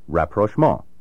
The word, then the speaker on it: Rapprochement: The word has been used in English since the early 19th century, but as you can hear, it retains its French pronunciation. Rapprochement.